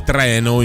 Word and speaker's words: treno: Si dice treno invece con la E aperta.